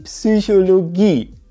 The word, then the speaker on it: Psychologie: Die Psychologie. Hier liegt die Betonung auf der letzten Silbe.